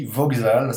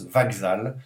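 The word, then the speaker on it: Vaxal (вокзал): Alors c'est écrit Voxal, ça se prononce Vaxal. Et ça veut dire la gare. V-O-K-Z-A-L.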